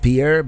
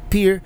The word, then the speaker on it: Pierre: And I've been reliably informed it's not pronounced Pierre, but Pierre.